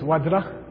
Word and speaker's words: Vajra: The real pronunciation is Vajra.